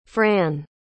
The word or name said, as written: fran